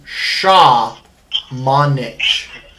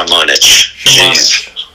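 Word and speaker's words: Šamanić: So I got the pronunciation on basketball reference because there wasn't a YouTube clip for it. Um, but it is Luca, obviously, and then the last name Shaw Monich. Shaw Shamonich. Like that.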